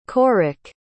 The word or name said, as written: corrick